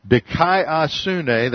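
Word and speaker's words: dikaiosune (δικαιοσύνη): The Greek word has to do with righteousness, dikaiosune. From the root D-K-D-I-K-E, dikaiosune, that's spelled D-I-K-A-I-O-S-U-N-E.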